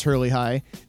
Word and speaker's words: Hierlihy: Yeah, that's how you pronounce Hurley High. It's not just the pronunciation on this guy, it's the spelling too. You would not really think of Hurley High when you see it spelled H-I-E-R-L-I-H-Y.